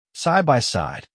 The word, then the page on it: side by side: American English